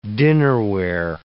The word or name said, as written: dinnerware